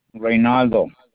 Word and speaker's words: Reynaldo: L-A-R-A. – L-A-R-A? – Yes. – And the last name? – Reynaldo. – Can you spell it? – It's R-E-Y-N-A-L-D-O.